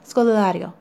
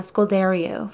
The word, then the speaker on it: Scodelario: It's Pirates of the Caribbean and it's Kaya Scodelario. It's, it took me forever to figure out how to say her last name, by the way. I would say uh, Scoldario for like the longest time, and then I saw a video of her promoting something.